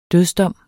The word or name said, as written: dødsdom